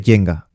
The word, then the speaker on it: Jenga: Bilbo nos matiza que no se dice Jenga, que se dice Jenga.